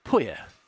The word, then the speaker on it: Pouille: On the men's side, I am on Lucas Pouille is the French. Let me spell that for you. Lucas, P-O-U-I-L-L-E. Lucas Pouille is, I believe, how you pronounce that in French.